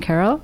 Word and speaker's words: Kerala: That's yes. That's correct pronunciation. – Kerala.